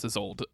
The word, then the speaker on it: Isolde: They went hard on the E, but I don't pronounce the E when I say it. I just say Isolde.